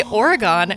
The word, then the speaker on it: Oregon: We say Oregon. And so when I moved out here, I was like, Oregon, Oregon, Oregon, like to train myself. – So do you guys know that we do pronounce it Oregon, but you just pronounce it Oregon? – Yeah, doesn't matter.